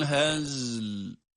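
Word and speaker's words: Hazl (هزل): Faslun ve ma huve bil hazl. – Bakınız, hazl kelimesinde de aynı. – Hazl. – Önce za cezimle okunuyor. Ara açılmadan yine lam cezimli olarak, yani sakin haliyle devamında okunuyor.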